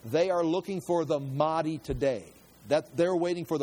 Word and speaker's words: Mahdi: I wrote it down as Mahdi. That's how you say it, Mahdi.